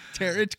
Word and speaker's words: tarot: Tarot cards? – Yeah. – Is that what it's called? – Tarot? Like carrot? – Tarot card. – How do you spell it? – T-A-R-O-T.